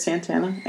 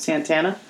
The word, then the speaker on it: Santana: Yes, it is Santana. Anna Banana Santana. Been called Banana my whole life because it rhymes with Santana.